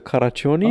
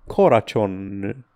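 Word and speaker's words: Karácsony: Ignațiu ne corectează și ne spune că nu se pronunță Caracioni, oh. Ah. Crăciun pe maghiară, ci Coracion.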